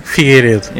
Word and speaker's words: Figueiredo: Figueiredo.